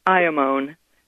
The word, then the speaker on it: Iamone: It's Iamon, and you spell it I-A-M-O-N-E, Iamon. When you break it down, it really is saying, I am one.